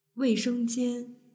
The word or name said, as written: weishengjian